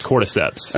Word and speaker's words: Ophiocordyceps: I believe it's pronounced Ophiocordyceps. – Yeah, is that correct? – Cordyceps.